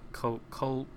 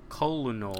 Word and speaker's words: Colonel: Colonel. I guess because it's kind of spelled like colino. – To read it is weird, yeah. – Colonel. – Colonel.